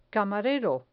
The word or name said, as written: camarero